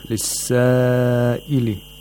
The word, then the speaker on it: لِلسَّائِلِ: So we have mad here. لِلسَّائِلِ. We have mad المتصل. واجب المتصل. You have to lengthen this mad to 4 or 5 vowel counts, because the sabab of the mad and the long vowel itself are in the same word.